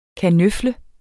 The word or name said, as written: kanøfle